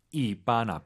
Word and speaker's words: 一般: 여러분들께 이반 앞에 흔올 수 있다는 걸 보여드리고 싶습니다. 왜냐면은 매우 보통이야. 말해주면 안 되는 것 같거든요, 우리는. 흔 이반. 사성일성. 이반. 일성 높이 발음하시고요.